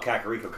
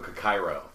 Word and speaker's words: Kakariko: I used to call Kakariko Kakairo because I wasn't actually reading it all the way through.